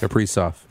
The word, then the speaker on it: Kaprizov: Kaprizov. – Kaprizov.